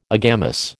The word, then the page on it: agamous: American English